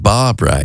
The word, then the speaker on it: Barbra: It's Barbra Streisand. But Barbra is B-A-R-B-R-A. I'm like, Barbra. Bra, bra, bra, bra, bra.